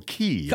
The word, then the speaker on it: Coco Cay: Yeah, but you pronounce it, you spelled it with a K, right? – Yeah. – Yeah. – Yeah. – This is C-A-Y. – Right. – And I know they pronounce it Key.